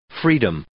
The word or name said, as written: freedom